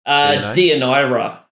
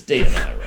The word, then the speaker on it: Deianira: Like Deianira. – Oh. – It's got a shitload of vowels, but it's Deianira.